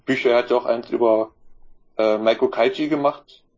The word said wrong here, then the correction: Meiko Kaji: Ich habe sie wieder falsch ausgesprochen, war? Ja, Meiko Kaji. – Kaji, Meiko Kaji, ja.